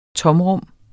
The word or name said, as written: tomrum